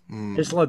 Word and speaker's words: Isla: and Brian Last pronounced the S in Isla Dawn like a pure fanny. So he called it, Isla Dawn or Isla Dawn? – Isla Dawn, I think.